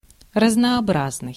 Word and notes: разнообразный: /rəznɐːˈbraznɨj/; raznoobraznyi

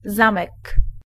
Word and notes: zip: /zɪp/; American English; [zɪp]

